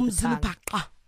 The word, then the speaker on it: Umzulupaka: The next one is Umzulupaka from Unlimited Budget. Did I say that right? – Yes. Okay. That was the correct click. It's the Q click, so it's at the front of Umzulupaka.